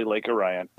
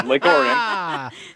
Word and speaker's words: Lake Orion: It's just about there's across the street from where I live is literally Lake Orion. And I just said it wrong now. Gosh, you guys got me all twisted. Lake Orion.